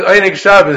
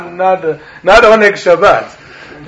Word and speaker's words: Oneg Shabbos: So the correct way to say it is Oneg Shabbos. If you don't like it, then call it Archion Ringelblum, not Oneg Shabbat.